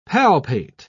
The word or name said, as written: palpate